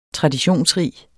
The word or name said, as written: traditionsrig